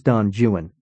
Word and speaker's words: Don Juan: "It's pronounced Don Juan," he said. "That's true. Much of the meter in Don Juan only works if you read Juan as bisyllabic."